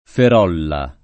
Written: Ferolla

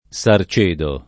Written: Sarcedo

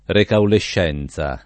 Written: recaulescenza

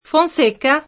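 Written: Fonseca

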